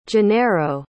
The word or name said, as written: genero